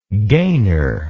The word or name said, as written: gainer